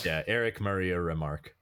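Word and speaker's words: Remarque: Eric, is it Remark? Just Remark? – Yeah, it is.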